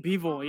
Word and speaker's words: Bivol: Bivol, is that how you pronounce it? – I think Bivol, yeah, Bivol.